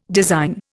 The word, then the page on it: design: Indian